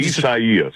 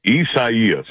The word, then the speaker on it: Isaias: Isaias. – Wait, I'm sorry, I stepped on you. What is it? – Isaias.